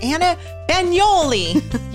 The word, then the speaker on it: Bagnuoli: I am here this week with my good friend, Anna Bagnuoli. – You got it. – Did I say it right? – Yes, you did.